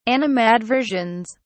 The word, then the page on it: animadversions: British English